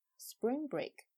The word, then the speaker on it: spring break: 比如说春假（Spring Break），还有呢啊音乐节（Music Festival），它们都是复合名词。所以他们就有这样的一个发音特点，重音在前边，一定要说 spring break，不能 spring break，这样就不行了。